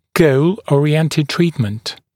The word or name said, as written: goal oriented treatment